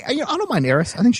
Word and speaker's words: Aerith: All right, next up, we have 13-seeded Ellis versus number 3-seeded Auron from FF10 versus number 14, fuck you, GameFAQs, it's pronounced Eris because we live in the United States, Gainsborough from FF7. Like, who translated that to A-E-R-I-T-H?